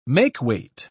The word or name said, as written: makeweight